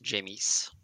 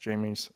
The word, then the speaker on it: Jameis: I believe it's pronounced Jameis. – I think you're right. It is pronounced Jameis.